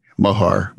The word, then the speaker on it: Moher: Moher. Moher. Right. but it's hard. It's hard. The cliffs of Moher.